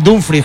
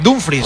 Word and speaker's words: Dumfries: Joder, no me sale Dumfries, que es lo que él dijo que quería que le llamaran, pero no, me cuesta muchísimo. – Bueno, si es como se escribe, tú como se escribe, se pone Dumfries por Dumfries. – No, no, si él dijo que Dumfries. – Pues ya está. Dumfries.